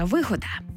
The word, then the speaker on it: вигода: Запам'ятати легко, як і все те, що дає добрі наслідки та гордо має й отримує наголос на першому складі. Це слово «вигода».